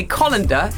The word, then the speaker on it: colander: He says we are pronouncing C-O-L-A-N-D-E-R wrongly. – I've no idea how I say it. – That's what we're supposed to be using today. It's one of the suggestions that we use a colander in my world. – Yes, yes. – And he says that we should say colander.